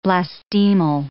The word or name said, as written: blastemal